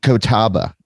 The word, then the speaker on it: Catawba: Catawba.